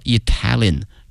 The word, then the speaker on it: Italian: 意大利这个词啊，同学们要小心了，它的名词和它的实用词呢，这个发音同学们一定要小心了。意大利呢是 Italy 啊，国名。如果说意大利的或意大利人叫 Italian，哎，所以说这个发音还是有区别的。